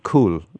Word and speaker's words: Coole: Cool, but it's spelled C-O-O-L-E, Cool Park. – C-O-O-L-E Park in near Galway.